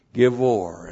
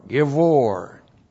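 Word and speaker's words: gibbor: And then we have the adjective givor. And We've seen this many times. If you've been a faithful attendant here, G-I-B-B, long O-R, Givor. And Givor literally means strong or mighty.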